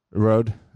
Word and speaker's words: Rode: Is that pronounced a Rohde caster? Is that how you pronounce that? – Rode. Actually, I got this. You can call it Rohde.